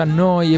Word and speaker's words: Neu: Si scrive Neu. Si pronuncia Noi e vuol dire nuova.